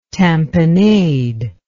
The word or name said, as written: tamponade